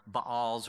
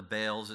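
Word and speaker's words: Baal: or the Baals, or the Baals, it can be pronounced both ways.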